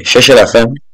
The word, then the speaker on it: Cherchez la Femme: I'm not sure if that's how what I called it before we played it, but that's how it's pronounced because I checked. I really don't know how to speak French, but it sounds much better. I might have probably said Cherchez.